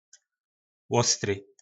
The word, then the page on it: lostry: po nasimu